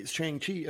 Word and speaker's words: Shang-Chi: How is it pronounced? – Shang-Chi. – Shang-Chi.